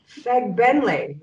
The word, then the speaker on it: Fegbenle: I am your host, and today I am joined by O.T. Fegbenle, right? – You did a pretty good job on that. – Yeah, I practiced. And then I wrote it down phonetically as well. – Right. Okay, great. – Did I do it right, though? – Yeah, you did it right. I mean, there's like an anglicized version of it, and then there's the like Yoruba pronunciation, but that's the perfect anglicized version of it.